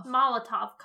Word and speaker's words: Molotov: Molotov cocktails.